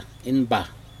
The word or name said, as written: inbah